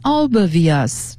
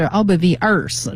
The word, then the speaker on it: obvious: 对了。好，这个 be well prepared, 我觉得经过咱们练这几个句子，希望能够让大家更熟悉。再来一个就是很显然，这个显然这个词呢，它的发音好多人是念不对的。形容词 obvious, 重音在 o 第一个音节上。课文中呢说 it's really obvious.But arrive in plenty of time、啊、说这个非常的显而易见，但是呢我还是得说一下的意思，你还是要打出充分的时间， arrive in plenty of time，及早到达。这个 obvious 总有人发成 obvious 或者是 obvious，